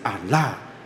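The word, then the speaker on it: 阿 拉 (الله): 那这个附带讲，有人会说那个回教徒是那个信阿拉，我后来听到我有一个姐妹去科威特留学，她会阿拉伯语，她回来说传道那个有时候是我们的误会，她其实那个阿拉那是阿拉伯语的发音是阿拉，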